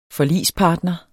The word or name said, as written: forligspartner